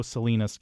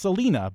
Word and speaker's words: Salina: Not only that, there is a town in Kansas, a city in Kansas, without the S on the end, that you might think is pronounced Selena, but it is not. It is pronounced Salina.